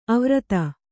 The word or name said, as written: ಅವ್ರತ